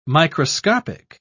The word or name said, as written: microscopic